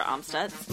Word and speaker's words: Amstutz: So you can find me on all social media platforms at Sandra Amstutz.